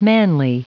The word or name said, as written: manly